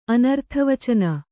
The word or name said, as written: ಅನರ್ಥವಚನ